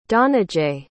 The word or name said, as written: dhananjay